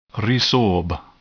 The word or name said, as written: resorb